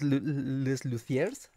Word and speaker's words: Les Luthiers: Ah, mira, Gus Palomo menciona como igual, ya, ya jubilados, de hecho, hace poco que se retiraron les, les Luthiers, así se pronuncia.